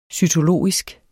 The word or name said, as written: cytologisk